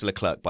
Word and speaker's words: Leclerc: Leclerc, and it's pronounced Leclerc, by the way. Don't worry, don't be embarrassed. Easy mistake to make.